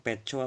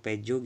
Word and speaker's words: Petjo: tulisannya sih p e t j o mungkin bacanya peco atau pejo gitu soalnya kan ejaan ejaan lama kan p t j kan itu dibacanya c